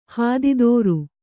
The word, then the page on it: ಹಾದಿತೋರು: hādi tōru